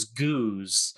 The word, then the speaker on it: Gooz: My nickname growing up was Goose, G-O-O-Z. And the reason it Goose is because I couldn't pronounce goose correctly.